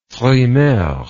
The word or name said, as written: frimaire